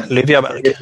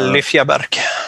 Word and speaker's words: Lyfjaberg: Yeah, Lyfjaberg. – Yeah, I don't know how to say it. – Yeah, Lyfjaberg.